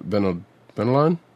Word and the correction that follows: Batalon: I can't remember how he pronounces his name, but I think it's just Batalon. – Batalon, like the way.